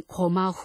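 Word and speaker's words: 고마 (高麗): 신사 책임자인 고마 후미야스 씨는 약광의 직계 후손이라고 합니다. 고마는 고려의 일본어 발음입니다.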